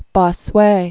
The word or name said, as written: Jacques Benigne Bossuet